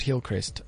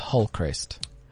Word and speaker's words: Hillcrest: Just one correction in your story there. It's not Hillcrest. It's Hullcrest.